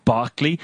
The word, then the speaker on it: Berkeley: Oh, don't you know how to pronounce Barkler? But it's spelled B-E-R-K-E-L-E-Y. – Yeah. – Berkeley, but you want me to pronounce it Barkley?